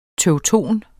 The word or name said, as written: TEUTONER